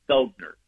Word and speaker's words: Felgner: Your co-patent holder, or the co-inventor, if you will, of this tech is Dr. Flegner, right? Philip Felgner, excuse me. – His name is actually pronounced Felgner. – Felgner, yeah.